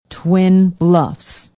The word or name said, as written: twin bluffs